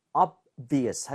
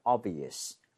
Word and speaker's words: obvious: 그런데 여러분 이 B죠. 네. 너무 세게 내려고 할 필요 없어요. 거의 발음이 안 된다고 봐도 돼요. 왜냐하면 그 바로 뒤에 있는 V가 더 영향을 받으면서 끌고 가요. 그래서 obvious 하지 마시고요. obvious 이런 식으로 너무 앞에 B 신경 쓰지 말기로.